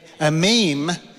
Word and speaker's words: Emim: Then a meme, everyone say a meme. I know how to pronounce this one. It literally is like a meme.